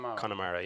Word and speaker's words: Connemara: Connemara. – Connemara, yeah.